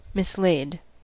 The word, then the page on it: mislaid: American English